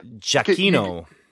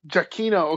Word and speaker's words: Giacchino: Giacchino. Giacchino.